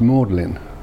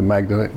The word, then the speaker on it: Magdalene: But anyway, Mary Magdalene, you know, it's spelled Mary, it's Magdalene College, I think Oxford. It's pronounced Magdalene. And people there laugh themselves silly when some non-English person says, you know, can you tell me how to get to Magdalene?